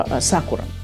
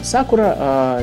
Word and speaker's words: Сакура: Мы послушаем песню, которая называется Сакура. Да, Сакура, или Сакура, как вам больше нравится. Я почему-то именно так делаю ударение со времен Евгения Маргулиса и его Сакура, Катана Саке, песня от группы «Машина времени».